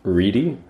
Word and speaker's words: Ready: Last name, Reedy, spelled like Reddy. – Damn. – Very Damn. – Deceiving. – R-E-A-D-Y.